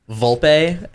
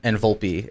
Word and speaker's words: Volpe: It's always like a combination of like Volpe and Volpe. It's always like a middle ground with the accent.